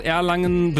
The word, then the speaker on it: Erlangen: Auf jeden Fall Erlangen, und das hat auch Dimi geschrieben, der sagt, er kommt aus Erlangen, Betonung aufs erste E.